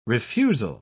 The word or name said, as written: refusal